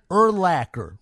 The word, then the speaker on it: Urlacher: Brian Urlacher. Rhymes with linebacker.